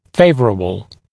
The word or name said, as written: favorable